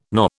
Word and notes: Noh: British English

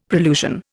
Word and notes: prolusion: Indian

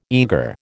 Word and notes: eagre: American English

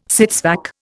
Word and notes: sits vac: Indian

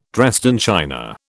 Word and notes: Dresden china: British English

